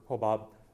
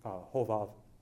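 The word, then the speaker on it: Hovav (חובב): But also with Hovav or Hobab, modern Hebrew pronunciation, Hovav, that here you have this Midianite who's going along the way.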